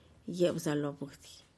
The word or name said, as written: yebzalobuhdih